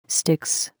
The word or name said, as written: stiches